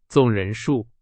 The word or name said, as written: 总